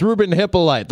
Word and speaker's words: Hippolyte: I thought it was Hippolyte. I was going to put the long E on the end. I thought it was Hippolyte. – Right. – So it's Ruben Hippolyte, the linebacker out of Maryland to the Bears at number.